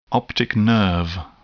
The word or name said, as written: optic nerve